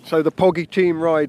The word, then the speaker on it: Pogi: So you are the, is it the Poggi team or the Poggi team? How do you say that? – It's Poggi team.